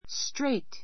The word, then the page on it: straight: stréit; ストレイト